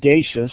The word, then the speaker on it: Dachos: My name is Jim Dachos, and everyone mispronounces my name. It's spelled like nachos, but it's like bodacious.